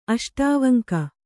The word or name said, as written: ಅಷ್ಟಾವಂಕ